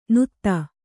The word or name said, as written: ನುತ್ತ